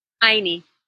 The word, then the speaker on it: ayni: It is our birthright to live free in integrity and an expanded joy, co-creating our lives in A-Y-N-I. How do you say that? – Aini. – Aini.